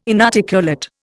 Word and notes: inarticulate: Indian

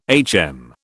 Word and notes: HM: British English

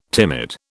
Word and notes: timid: British English

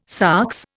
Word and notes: sox: Indian

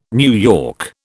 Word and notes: NY: British English

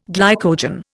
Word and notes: glycogen: Indian

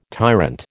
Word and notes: tyrant: American English

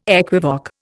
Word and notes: equivoque: Indian

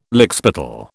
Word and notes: lickspittle: British English

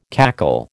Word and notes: cackle: American English

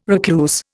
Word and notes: recluse: Indian

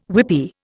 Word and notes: whippy: Indian

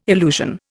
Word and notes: illusion: Indian

